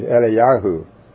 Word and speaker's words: Eliyahu: But the proper spelling and the proper pronunciation is Eliyahu, which is a seven-letter word.